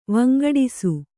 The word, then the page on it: ವಂಗಡಿಸು: vangaḍisu